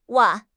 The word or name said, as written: wan4